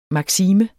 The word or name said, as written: maksime